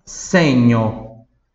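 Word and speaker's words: segno: Próxima palavra, senho.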